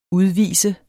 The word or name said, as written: udvisende